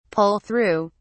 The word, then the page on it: pull-through: British English